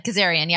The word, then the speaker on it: Kazarian: Kazarian? – Kazarian, yeah.